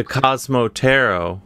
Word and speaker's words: Cosmo Tarot: We'll have you back before the book, The Cosmo Tarot. Am I saying that right? – Yeah.